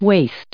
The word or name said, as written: waist